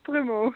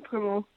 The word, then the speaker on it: Prummel: Ja, dat is Prummel. Jacqueline Prummel. – Ja, wij dachten al zou er een umlaut op moeten, Prummel, maar... – ja, sommige mensen zeggen dat inderdaad, maar het is eigenlijk ook wel iets mooier, maar het is gewoon plat Prummel.